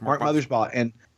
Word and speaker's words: Mothersbaugh: Mark Mothersbaugh. – Yeah, Mark Mothersbaugh.